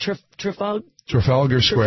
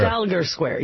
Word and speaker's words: Trafalgar Square: Trafalgar Square. – Trafalgar Square, yeah.